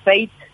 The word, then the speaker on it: Faith: La número uno en el mundo se llama Fate, se escribe F-A-I-T-H, Popcorn.